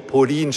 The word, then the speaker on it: Pauline: Pauline Marie Charicot, Pauline geschrieben. Einige haben geglaubt, ich spreche von einer Polin. Ja, also das ist nicht geografisch Polen, sondern Pauline. Pauline spricht man es. Die Franzosen sind komisch. Die schreiben was und sprechen es ganz anders aus.